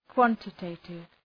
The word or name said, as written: quantitative